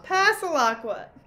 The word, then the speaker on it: Passalacua: Passalacua.